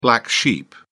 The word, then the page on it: black sheep: British English